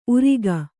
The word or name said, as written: ಊರುಗ